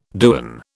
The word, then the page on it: dewan: British English